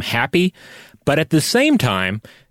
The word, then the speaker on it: Hapy: He is a god whose name in English is usually spelled H-A-P-Y. And I have not, I searched in vain to find a correct pronunciation for this name and could not, but I think it's Hapi.